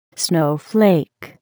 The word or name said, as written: snowflake